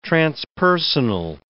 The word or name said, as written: transpersonal